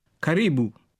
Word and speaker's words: Karibu: Karibu.